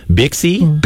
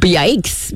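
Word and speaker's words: Byxe: If you look at the name, you're going to be confused because it's B-Y-X-E Week. So you are struggling with, well, how do you say this? Bixie? – Bikes. Bikes. Bixie? – Bikes. It's a Mennonite Bikes. Thing. It's Bikes Week.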